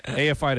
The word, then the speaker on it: AFI: Davey Havoc, Jade Puget, AFI, to say it right that time.